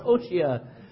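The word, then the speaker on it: Hoshea: It's actually pronounced O'Shea.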